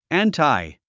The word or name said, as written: anti